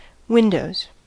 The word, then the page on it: windows: American English